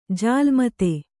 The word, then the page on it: ಜಾಲ್ಮತೆ: jālmate